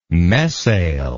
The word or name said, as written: mesail